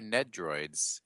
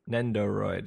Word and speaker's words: Nendoroid: I never know how to say this word. Nendoroid? – Nendoroid? – I thought they were Nedroids. NED. – I think it's Nendoroid. – Nendoroid, yeah, because Nendoroid. – there's double O's in there.